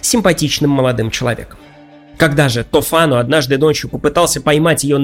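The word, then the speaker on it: Tofano: В ней повествуется о богаче и ревнивце Тофано. Итальянцы произносят это имя как Тофано, но вы уж простите, я сохраню то произношение, к которому приучил меня Ерорд Масс, иначе говоря, третий участок, где мы обсуждали когда-то Декамерон с дядей Араиком.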